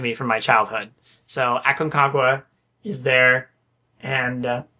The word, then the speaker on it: Aconcagua: Aconcagua? I hope I'm pronouncing it right. – Yeah. – Aconcagua.